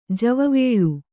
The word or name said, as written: ಜವಿವಲೆ